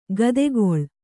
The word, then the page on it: ಗದೆಗೊಳ್: gadegoḷ